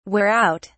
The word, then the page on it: wear out: British English